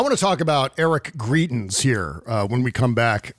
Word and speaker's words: Greitens: I'm going to go with your pronunciation, which is Greitens. We're going to go with Greitens on the show.